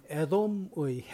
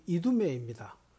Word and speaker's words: 이두메: 히브리 발음 에돔의 헬라 발음이 이두메입니다.